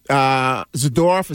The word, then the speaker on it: Zadorov: And even the Zadorov, is that how you say his name? – Yes.